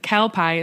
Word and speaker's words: kelpie: kelpie. Is that how you pronounce kelpie.